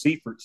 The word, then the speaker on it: Seifert: I guess if we're in Germany, it is pronounced Seifert. And I guess technically if we're over here, it's Seifert. So I answer to either one.